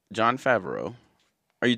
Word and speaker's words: Favreau: Those I don't know how to pronounce his name. John Favreau. – Favreau. – Yeah, Favreau. John Favreau.